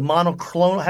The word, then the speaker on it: monoclonal: One of the things he threw at it was the and I can't pronounce it, but the monoclonal.